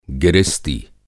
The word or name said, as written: गिरिस्ती